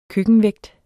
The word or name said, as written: køkkenvægt